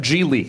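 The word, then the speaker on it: Gigli: It's pronounced G-Li. G-Li. – Yeah, G-Li. – But it G-Li. – Is. – I can get it. – It's spelt Gigi, but it's pronounced pronounced G-Li.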